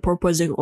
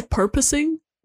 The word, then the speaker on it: porpoising: Purposing? What do you say? – Porpoising. – Porpoise? – Like a porpoise. Like a dolphin.